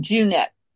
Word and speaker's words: Jeanette: It's like the month of June, Junette.